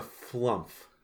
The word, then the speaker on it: Flumph: Because it's a Flumph. – I'm not sure I know what a Flumph? – Flump. You're saying it wrong.